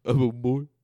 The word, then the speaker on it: Omundson: Nope. It's definitely pronounced like that. Omundson. And you have to go Omundson every time you say it. But do you know that guy? – Omundson.